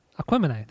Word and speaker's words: Aquemini: So my number three is Aquemini.